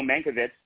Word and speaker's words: Mankiewicz: Herman and Joe Mankiewicz, a dual portrait. And, you know, I know I pronounced that with the kind of the old world pronunciation, Nick.